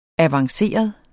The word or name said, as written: avanceret